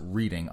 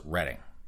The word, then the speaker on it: Reading: And if you folks in Pennsylvania pronounce it Reading, I apologize. Up here in Massachusetts, even though it says Reading, everyone up here calls it Reading.